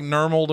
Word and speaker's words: Nirmal: And I'm probably mispronouncing. It's Nirmal. – I would still call that Nirmal. – Sounds like Nirmal to me. – Go for it. – Nirmal Mulye. M-U-L-Y-E. M-U-L-Y-E. M-U-L-Y-E is right. Yes. – M-O-U-S-E.